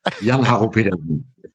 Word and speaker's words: Jann-Harro Petersen: Please correct my pronunciation if I've got that wrong. – No, that's in English, I think it's correct. Jann-Harro Petersen.